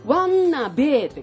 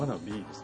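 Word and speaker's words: wannabe: で、それをね、あのローマ字で書いてあるんですけど、まあアルファベットを並べて書いてあるんですけど、ワンナベーって書くんですよね。ねよくワンナベーワンナベーってね。 – ワンナベーって読んでました。 – よく誰ですか？ – あれワンナベーって書いてワンナビーなんですか？ – ワナビーですね。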